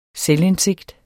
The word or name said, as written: selvindsigt